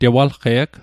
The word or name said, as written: tewalqe'g